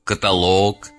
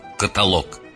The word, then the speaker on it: каталог: Я об ударении. Договор, некролог, каталог. Я сознательно чуть-чуть утрированно сделал правильное ударение. Договор, некролог, каталог.